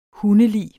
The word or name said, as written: hundelig